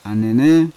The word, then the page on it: anene: Yawa